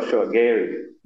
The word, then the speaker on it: Gehry: Gehry, am I saying that right? – Yeah, sure. Gehry.